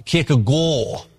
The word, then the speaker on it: Kierkegaard: In his enigmatic but brilliant The Sickness Unto Death, Kierkegaard, or Kierkegaard, as my pipe-smoking Danish PhD roommate made clear to me it should be pronounced when we were studying together at Cambridge, Kierkegaard explained how, in a sense, failure is the heart of the Christian revelation.